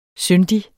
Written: syndig

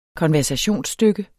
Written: konversationsstykke

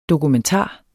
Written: dokumentar

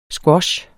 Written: squash

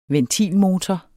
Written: ventilmotor

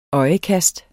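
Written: ØJEKAST

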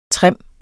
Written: trim

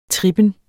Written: trippen

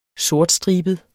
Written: sortstribet

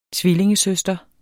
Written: tvillingesøster

